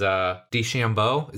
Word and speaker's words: DeChambeau: DeChambeau. Is that how you pronounce mm-hmm. his last name? Dude, yeah.